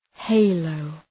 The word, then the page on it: halo: ‘heıləʋ